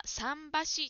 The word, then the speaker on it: 三橋: 答えの中でジムさんが三橋と言っていたところがありますが、この漢字は三橋と読みます。三橋、三橋です。